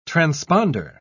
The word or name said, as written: transponder